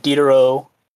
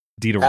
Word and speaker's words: Diderot: T silent, just Diderot. – Diderot.